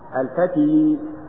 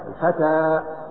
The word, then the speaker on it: الفتى: الفتى لا تقل على الياء الفتي، هذه الياء لا، الفتى وإن كتبت ياء فهي ألف.